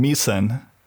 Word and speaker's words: Misen: And I picked the name Misen, M-I-S-E-N,